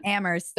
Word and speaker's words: Amherst: I don't get the joke because they say, isn't it Amherst? Because they say the only thing silent, they say, a joke you hear a lot is that in Amherst, Only the H is silent.